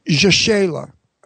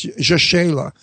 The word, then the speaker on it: Rachele: Rachele. – I wish her name was Mary, but anyway, it's Rachele.